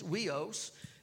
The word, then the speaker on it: huios: It comes from two Greek words, and the first one is weos. That's spelled H-U-I-O-S, but it's pronounced weos, and it means a man, not a boy.